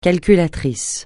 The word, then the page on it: calculateur: francais